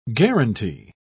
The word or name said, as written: guaranty